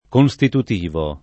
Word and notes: constitutivo: Italian

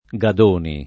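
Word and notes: Gadoni: italiano

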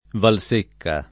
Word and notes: Valsecca: Italian

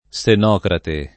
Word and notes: Senocrate: lingua italiana

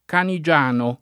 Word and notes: Canigiano: lingua italiana

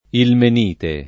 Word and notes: ilmenite: lingua italiana

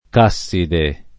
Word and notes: casside: k #SS ide

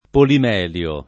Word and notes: polimelo: lingua italiana

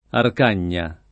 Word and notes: Arcagna: lingua italiana

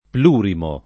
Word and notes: plurimo: lingua italiana

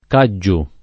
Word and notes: Catgiu: lingua italiana

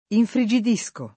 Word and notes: infrigidire: lingua italiana

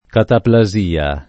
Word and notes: cataplasia: Italian

